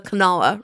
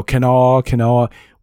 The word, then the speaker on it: Kanawha: And fortunately, we know how to pronounce the Kanawha River. – Well, exactly. Well, you know, Kanawha.